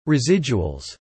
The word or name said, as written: residuals